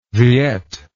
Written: viet

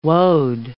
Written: woad

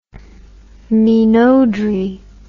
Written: minauderie

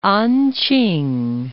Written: An-ch'ing

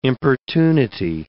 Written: importunity

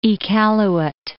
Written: Iqaluit

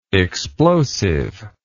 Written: explosiveness

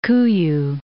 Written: Kuiu Island

